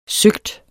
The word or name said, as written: søgt